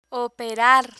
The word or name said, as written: operar